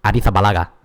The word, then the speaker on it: Arrizabalaga: Chelsea goalkeeper Kepa Arrizabalaga. – Perfect. – Sorry, no, you have to roll. You have to pronounce it with a soft Z. Arrizabalaga.